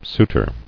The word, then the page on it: Souter: Sou·ter